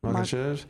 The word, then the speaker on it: Makhachev: Makhachev.